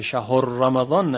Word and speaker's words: شهر رمضان: بعد دقت کنیم که ها ساکن تلفظ بشه، یک وقت مذموم نشه، شهر رمضان نشه.